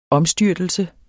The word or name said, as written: omstyrtelse